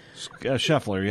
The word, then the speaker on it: Scheffler: I think it's how you pronounce his name. – Scheffler, yeah. – Scheffler.